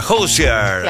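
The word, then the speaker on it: Hozier: Hoosier. – Claro. – Ahí te lo dije bien, Malena Novela.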